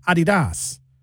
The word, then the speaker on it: Adidas: In fact, we're saying it wrong. The Germans say Adidas. That's how they say it.